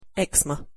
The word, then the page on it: eczema: English